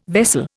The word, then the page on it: vessel: Indian